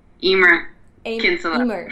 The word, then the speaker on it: Emer Kinsella: Emer Kinsella.